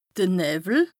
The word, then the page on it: de Nëwwel: Bas-Rhin; Strasbourg